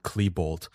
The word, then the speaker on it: Klebold: Kill Eric Harrison, Dylan Klebold. By the way, is how we pronounce it, Jackson.